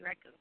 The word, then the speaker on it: Raghu: R-A-V as in Victor I. – R-A-V-I. – And then Raghu, R-A-G-H-U. – R-A-G-H-U.